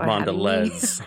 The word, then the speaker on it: Mondelez: Insights and Analytics with Mondelez International. Thank you for joining us. – I would have said Mondelez. – Mondelez. – Yeah, that's my bad. – Mondelez. – See, there we go.